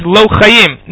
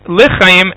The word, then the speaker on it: lechayim: Because the problem with saying it with a kamatz or a patachani, l'chayim, right, a person might come to say that l'chayim means lo'chayim, not life. Right, the same type of confusion here, that if you have an aleph in there, or if you pronounce it la or the itnesba, right, it will sound like lo, and it'll be a problem.